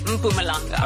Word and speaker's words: Mpumalanga: Who yesterday, in the traffic bulletin, I said, Mapumalanga. – Yes. – I said it so confidently. Polly looked at me and she said, is that what you call it? And I was like, yes. They educated me and said it's Mpumalanga.